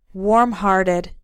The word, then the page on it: warmhearted: American English